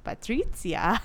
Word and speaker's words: Patrizia: Gets upset because Patricia, and they pronounce her name Patrizia, which I like a lot.